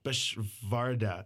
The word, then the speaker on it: Beschwerde: Oh, actually, I said it wrong. It's Beschwerde. It's like V sound, but whatever.